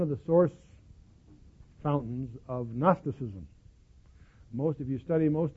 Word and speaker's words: Gnosticism: Now, you'll run into this term Gnosticism, spelled with a G, G-N-O-S, so forth.